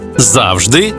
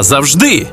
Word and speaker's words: завжди: Ось 5 українських слів із подвійним наголосом. Байдуже, байдуже, завжди та завжди, мабуть та мабуть, також і також, помилка і помилка.